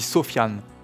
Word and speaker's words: Sufjan: Cette semaine, Indébara vous recommande un songwriter américain qui s'appelle, alors j'ai appris ça en fait, comment il fallait le prononcer. En fait, ça s'écrit Sufjan, S-U-F-J-A-N. En fait, on dit Sofiane Stevens, donc, est un songwriter américain qui est né en 1975.